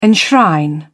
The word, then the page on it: enshrine: British English